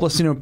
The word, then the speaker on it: Blasino: At Paul Blasino, P-O-L is how my first name is spelt, and my last name is spelt like it sounds.